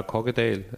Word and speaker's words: Kokkedal: Du kommst aus Kokkedal, habe ich das richtig ausgesprochen? – Ja, Kokkedal.